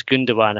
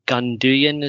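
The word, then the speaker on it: Gündoğan: Because I think he told me, because he's Turkish or has Turkish roots, that the person that is, people pronounce Gundogan, I think he said something like Gunduyan is probably the closest, like easy pronunciation in English.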